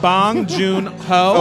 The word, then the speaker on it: Bong Joon Ho: That was Boon. – Bong Joon Ho.